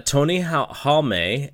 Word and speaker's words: Halme: Tony Halme, as Marcus Mack told me, that's the way to pronounce it, had told Titan months back that he needed October off for the New Japan Tag Tournament.